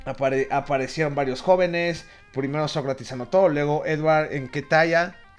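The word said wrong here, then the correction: Nketiah: En Ketia, perdón.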